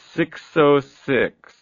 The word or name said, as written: six o'clock swill